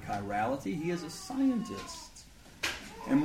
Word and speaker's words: chirality: And the word is chirality. If I would spell it correctly, that would be good. Chirality. C-H-I-R-A-L-I-T-Y.